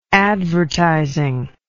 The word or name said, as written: advertising